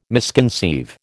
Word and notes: misconceive: American English

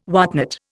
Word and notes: whatnot: Indian

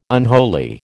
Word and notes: unholy: American English

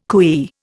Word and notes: cooee: Indian

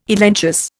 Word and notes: elenchus: Indian

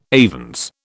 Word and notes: avens: British English

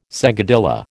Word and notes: seguidilla: American English

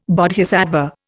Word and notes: Bodhisattva: Indian

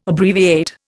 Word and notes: Abbreviate: Indian

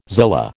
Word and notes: zillah: American English

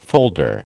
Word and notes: folder: American English

